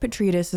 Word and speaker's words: Petridis: Another thing to keep in mind, and this is from The Guardian, Alex, how do you say his name? I mean, he's such a great writer. He's just... – Who? – Alex Petridis? – I think Petridis is right.